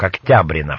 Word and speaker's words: Октябрина: Ударение на второй слог, октябрина фамилия.